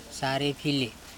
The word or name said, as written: sare pHile